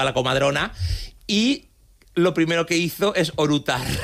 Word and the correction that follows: eructar: Con orutar es eructar.